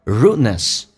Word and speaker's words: rudeness: Look down upon, rudeness show poor education. 这边有一个第一个 rudeness, D 跟 N 组合在一起，两个辅音在美式发音只用发后面一个叫 rudeness, 所以气流阻塞一下，